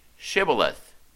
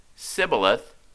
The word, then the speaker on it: Shibboleth: If he said no, then they would say to him, "Say Shibboleth." And if he said Sibboleth, for he could not pronounce the word correctly, the Gileadites would seize him and kill him at the fords of the Jordan.